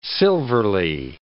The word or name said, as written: silverly